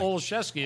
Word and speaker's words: Olszewski: Olszewski, and it's pronounced nothing like it's spelled. It's sort of like the Polish, revenge on the language.